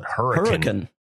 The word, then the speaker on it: Hurricane: It's spelled Hurricane, but they call it Hurricane.